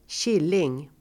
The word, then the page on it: killing: [²tj'il:ing]